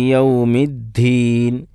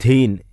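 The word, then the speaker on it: യൗമിദ്ദീൻ (يوم الدين): അപ്പോൾ ദാലിൻ്റെ ഉച്ചാരണം എന്ന് ശ്രദ്ധിക്കുക, അത് താവുമായി കൊണ്ട് ചേരാൻ പാടില്ല. അതാണ് ഈ ധീൻ ലിക്കി യൗമിദ്ദീൻ, തീൻ എന്നോ ധീൻ എന്നോ ദാല് ഹ കൂട്ടിച്ചേർത്തുകൊണ്ട് ഉച്ചരിക്കുന്നത് തെറ്റാണ്.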